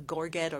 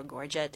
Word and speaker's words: gorget: And that neck shininess is called a gorget or gorget, depending on how you like to pronounce it.